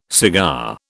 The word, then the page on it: cigar: British English